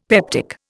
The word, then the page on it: peptic: Indian